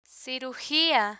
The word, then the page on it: cirugia: spanish